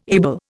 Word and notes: able: Indian

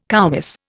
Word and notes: koumiss: Indian

